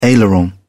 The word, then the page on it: aileron: British English